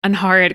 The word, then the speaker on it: Angharad: Angharad, because I was like, I remember we had to practice it a lot.